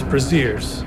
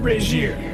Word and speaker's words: brazier: You say, "Before I order, I want to know why your sign advertises brasiers." Ingrid replies, "It isn't an ad for brasiers. It's pronounced brazier.